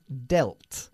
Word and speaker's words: dealt: Cuyo pasado, aunque se escribe casi igual, se pronuncia dealt.